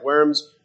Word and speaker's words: Worms: Notice, having considered Luther's conversion, 95 theses, Heidelberg Disputation, and 3 treatises, we come this week to consider his appearance at Worms.